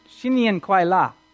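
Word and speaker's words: Xinyin Kuai La (新年快乐): Can you tell us how to say it? Xinyin Kuai La.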